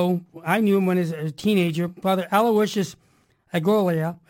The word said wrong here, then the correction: Ellacuria: How do I pronounce the last name? – Elicoria.